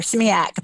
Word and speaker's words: SMEAC: Am I am I saying that right? – Yeah, you are SMEAC. – That's right. exactly right.